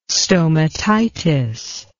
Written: stomatitis

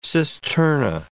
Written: cisterna

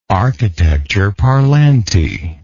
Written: architecture parlante

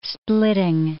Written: splitting

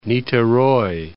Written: Nictheroy

